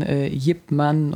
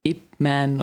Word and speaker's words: Ip Man: In diesem Film geht es ja um diesen großen Lehrer von Bruce Lee, der ja je nachdem, wo man sich befindet, unterschiedlich ausgesprochen, Yip Man oder Ip Man oder was auch immer, gibt es verschiedenste Versionen, der eben von Ende des, also ich glaube, der ist Ende des 19.